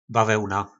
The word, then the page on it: bavelna: po nasimu